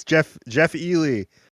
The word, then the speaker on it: Elie: Nope, didn't get it right at all, but that's fine by me. It's Jeff Elie.